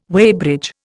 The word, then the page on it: weighbridge: Indian